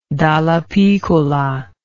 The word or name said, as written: Dallapiccola